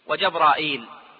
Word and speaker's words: جبرائيل: جبريل نطقها عند العلماء على نوعين: جبريل وجبرائيل.